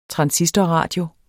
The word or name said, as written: TRANSISTORRADIOERNE